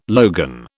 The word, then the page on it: logan: British English